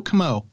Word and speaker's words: Comeau: Yeah, it's real simple. It rhymes with Joe. Joe Comeau.